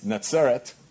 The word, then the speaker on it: Netzaret: We call the place where they lived Nazareth, N-A-Z-A-R, but really it's Netzaret, you would pronounce it.